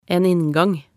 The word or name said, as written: en inngang